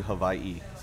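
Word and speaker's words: Hawaii (Hawaiʻi): It's called an Okina, so it's actually Hawaii. So you actually pause between the I's. That's the proper way to say it.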